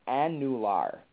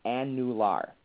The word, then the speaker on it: annular: And that's why I purposely make my students pronounce it as annular. And it's spelled A-N-N-U-L-A-R.